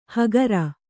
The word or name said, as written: ಹಕಾರ